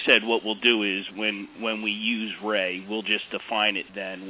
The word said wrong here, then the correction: RAE: You see, you just pronounced it ray, not R-A-E. – R-A-E, I'm sorry. R-A-E.